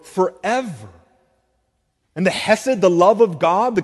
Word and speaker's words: hesed: His steadfast love, hesed, is the Hebrew word. If you want to say it right, you got to get the phlegm going.